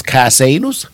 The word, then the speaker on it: Casanus: It is called Casanus, and that's spelled C-A-S-A-N-U-S. You heard it right, Casanus.